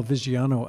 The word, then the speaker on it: Viggiano: We are back and we're continuing our conversation with Gordon and Jill Viggiano.